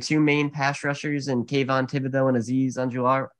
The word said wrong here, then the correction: Ojulari: and Anjular. Uh, I'm sorry, I butchered that. Ojulari. – Ojulari.